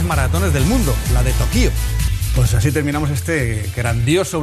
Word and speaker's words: Tokio: Lo he buscado y según Wikipedia, Tokio se dice así en japonés. Tokio.